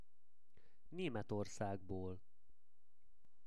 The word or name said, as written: Nemetorszagbol